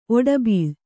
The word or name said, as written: ಓಡುಬಿಲ